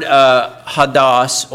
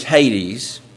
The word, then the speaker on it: Hades: The first one is the word hadas, or we would pronounce it in English, Hades.